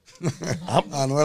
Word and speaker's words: Anuel: Yo, Yo, Anuel, am I saying his name wrong? I pronounce it too. Is it Anuel? It's Anuel or Anuel? – Anuel, I wrote you straight.